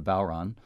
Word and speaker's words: bodhran: Jeff, I'm looking at some more wonderful adjectives in your biography here, and in particular regarding your playing of the bowron, which, for those who haven't heard that term, it's spelled in a rather funny way if we're used to regular English spelling, B-O-D-H-R-A-N.